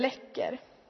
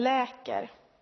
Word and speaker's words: läcker: Om en vattenkran i köket droppar lite fastän den är stängd, så säger vi att den läcker. Men sen finns det vissa som inte riktigt kan uttala det där ordet ordentligt, utan de säger att den läker. Läker och läcker.